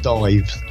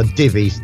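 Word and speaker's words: Divez: It's as simple as that, and it's D-I-V-E-Z, they're spelling Divvy's. I know it's pronounced Divvy's because fortunately for us, unlike a lot of bands, they actually tell us how to pronounce it in the press release. Otherwise, I might have called them Dives. They are Divvy's, D-I-V-E-Z.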